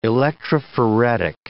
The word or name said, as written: electrophoretic